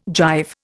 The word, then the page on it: jive: Indian